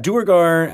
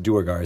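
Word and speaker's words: Duergar: Dwergar. I always pronounce it Dwergar. Is that wrong? – I do, too.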